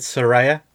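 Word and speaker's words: Saraya: The, The she said on Twitter today, you pronounce it Soraya.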